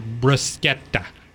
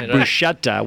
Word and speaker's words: bruschetta: It was your bruschetta. – Okay. Perfect. Perfect pronunciation. Okay. Don't tell the Olive Garden that it's pronounced bruschetta because they have to change all their ads because they always say bruschetta, which is what I was saying.